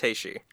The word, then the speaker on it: Teishi: Is it T-E-I-S-H-I? – Yes. – Taishi.